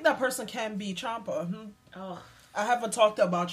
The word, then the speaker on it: Ciampa: I say Ciampa. – Yeah, it's Italian. The C-I makes a C-H sound, so it's Ciampa.